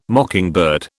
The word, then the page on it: mockingbird: British English